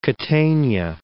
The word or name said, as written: Catania